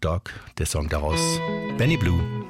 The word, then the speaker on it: Bleu: Wie die Liebe für Vicky schreibt sie auch der Nachname des nächsten Musikers, also B-L-E-U. Ausgesprochen wird er aber als Blue, Benny Blue.